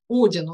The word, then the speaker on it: Один: Есть Бог, Один, ударение на первый слог.